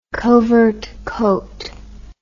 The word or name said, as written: covert coat